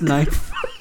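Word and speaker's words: K'nife: There's an apostrophe between the K and the N. No, there's but it's still pronounced Knife. – The K is silent.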